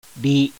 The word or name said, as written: RI